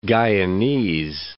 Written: Guianese

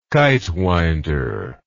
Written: kitewinder

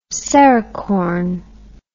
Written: serricorn

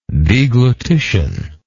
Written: deglutitious

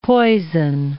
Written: poison

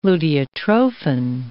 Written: luteotrophin

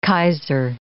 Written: Kaiser

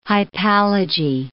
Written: hypallage

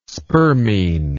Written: spermine

